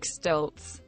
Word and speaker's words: Stoltz: Eric Stoltz. – There you go. – Oh, it is. You do pronounce the T.